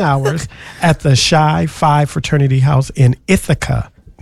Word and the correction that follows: Chi: It's pronounced Chi, but go ahead.